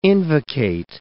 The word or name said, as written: invocate